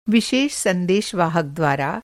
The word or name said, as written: संदेशवाहक